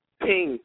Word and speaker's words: Ping: My name is Ping. – Oh, greetings. What did you say your name was? – Ping. P-I-N-G. Ping. – Oh, Ping. Like ping pong. – Yes, ma'am.